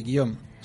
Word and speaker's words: Guillaume: Primero presento a Guillem Loens. – Ahí está, sí. Eh, ¿Sí? ¿Lo dije bien? – Sí, Guillaume. Se dice Guillaume porque, bueno, el nombre es francés y por eso tiene esa pronunciación.